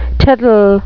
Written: tittle

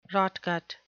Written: rotgut

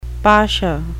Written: pasha

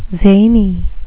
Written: zany

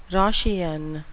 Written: Roscian

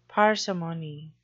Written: parsimony

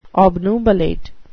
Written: obnubilate

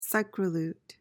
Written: psychrolute

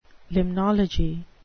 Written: limnology

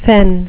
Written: fen

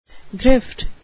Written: grift

